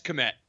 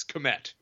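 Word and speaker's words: Kmet: Yeah, it's Komet. It looks exactly as it looks. Komet. Yes. I pride myself.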